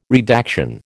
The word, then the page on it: redaction: American English